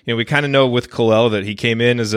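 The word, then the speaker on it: Kal-El: and the day that it was announced that Kalel, and is that the proper pronunciation? – Kalel, somewhere in that range, it kind of floats back and forth, but you got it.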